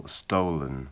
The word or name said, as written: stolen